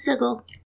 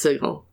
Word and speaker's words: Sigil: It is absolutely pronounced Sigil. It has been confirmed by Jeremy Crawford that it is supposed to be pronounced Sigil.